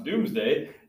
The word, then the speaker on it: Domesday: He is then going to create the Domesday Book, spelled Domesday, but pronounced Doomsday, as a survey of the landholdings held by himself and his vassals.